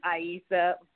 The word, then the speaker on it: Aissa: So it's you know, my husband's family goes by Aissa. Even they say okay. – It wrong. So the real pronunciation is Aissa.